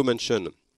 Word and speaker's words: Manchin: Joe Manchin, ça s'écrit M-A-N-C-H-I-N, machin, mais avec un N en plus.